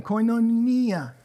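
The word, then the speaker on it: koinonia (κοινωνία): That word again, koinonia. Say it. Koinonia. I've been butchering that since like 2002.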